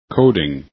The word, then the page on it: coding: english